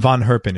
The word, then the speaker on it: Van Herpen: Ron, his last name is Van Herpen, V-A-N. Am I saying that right? – Van. Van. – Okay. Yeah. Ron Van Herpen. It's V-A-N-H-E-R-P-E-N. And the name of the band is Astrosonic, A-S-T-R-O-S-O-N-I-Q.